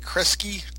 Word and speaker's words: Kresge: And then the precursor to Kmart, which we can only, I think we settled on, how are we pronouncing this? SS? – Oh, yes. – Kreskies? SS Kreskies? – Yeah, I think it was like Kresky.